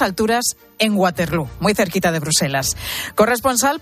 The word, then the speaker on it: Waterloo: Además, aquí lo pronuncian Waterloo.